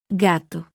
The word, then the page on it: g: letter; Spanish alphabet